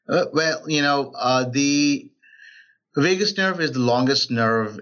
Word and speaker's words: vagus: I want to jump really quick to the vagus nerve.